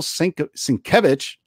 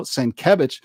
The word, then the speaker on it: Sienkiewicz: You know what's funny is Bill Sienkiewicz, say that right, you guys, not Sienkiewicz, it's Bill Sienkiewicz, recently talked about he wished the New Mutants movie would be better because people are saying how bad the movie is.